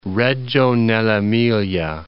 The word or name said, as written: Reggio nell'Emilia